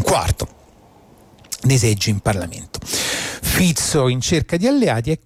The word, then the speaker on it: Fico: non si chiama Fico, cioè si scrive Fico ma si legga Fico, e che però adesso si trova nella condizione di dover trovare degli alleati.